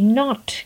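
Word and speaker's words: knot: नॉट कोई चीज़ नॉटेड है आपस में उलझ गई है तो उसकी स्पेलिंग है के एन ओ टी और प्रोनाउंसिएशन है नॉट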